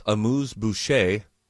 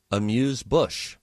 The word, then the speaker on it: amuse-bouche: I've always pronounced amuse-bouche, amuse-bush.